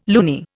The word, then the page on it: loony: Indian